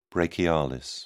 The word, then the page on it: brachialis: British English